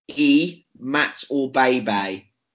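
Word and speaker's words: Imatorbhebhe: And the way it's supposed to read, I mean, I'm probably still going to get it wrong, is E-Mat-O-Bay-Bay.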